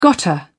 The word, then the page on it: gotta: British English